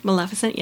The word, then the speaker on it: Maleficent: the discussion between the Evil Queen and Maleficent, did I say it right? – Maleficent, yes.